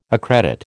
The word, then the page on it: accredit: American English